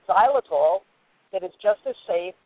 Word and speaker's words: xylitol: It's pronounced xylitol, but it starts with XY, xylitol, XYL. I-T-O-L, something like that.